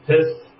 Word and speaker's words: PSST: Yeah, it was called PSST, like SST, but with a P before it. Pronounced piss, yeah.